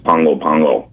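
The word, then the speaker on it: Pago Pago: So the name, as I said, the name of this first one is Pango, Pango, Tango. And, well, I think that's so great. And if nothing else, people will learn how to pronounce it. – Okay, well, actually, the pronunciation is Pango, Pango.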